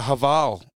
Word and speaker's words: Haval: It's actually called a Haval. – Havel, new, yes, yeah. Right, yes. – Havel, is that how it's pronounced? – I think it is a Havel, yeah.